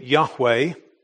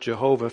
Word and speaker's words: Yahweh: All of the capital letters signify the name Yahweh, which at one time was thought to be pronounced Jehovah.